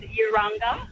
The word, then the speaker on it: Urunga: I'm just driving past Valor, and I thought I should call in and say it's pronounced Valor and Urunga and Ginnigayway.